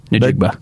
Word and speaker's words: Njigba: Njigba.